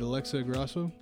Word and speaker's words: Alexa Grasso: And Alexa Grasso? – Correct.